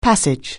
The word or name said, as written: passage